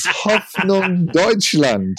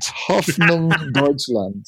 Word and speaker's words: Hoffnung Deutschland: Hoffnung Deutschland. Hoffnung Deutschland.